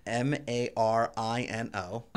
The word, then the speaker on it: Marino: How do you spell it? – M A R I N O. Uh, Marino.